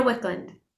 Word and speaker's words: Wicklund: Yeah, Hannah Wicklund.